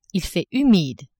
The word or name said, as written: il fait humide